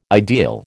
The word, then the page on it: ideal: American English